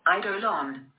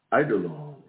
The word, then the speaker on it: eidolon: Eidolon. – Eidolon.